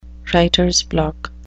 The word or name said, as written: writers block